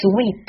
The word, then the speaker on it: sweet: 单独一个字母的单词 a，它的发音是好，我们再看 sweet，这里呢，w 都没有问题，就是 double e 的发音 e，sweet，一定要把这个长音 e 念到饱满，念到极致。如果懒懒的发音，就变成 sweet。